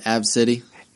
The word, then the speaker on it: ABCDE: Her name was ABCDE. – Pronounced Ab City.